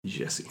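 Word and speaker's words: Gyasi: Horace Spencer's hands and Giassi Zardes' feet. Very similar in a lot of ways. That's a U.S. soccer reference. – I believe that's pronounced Jesse.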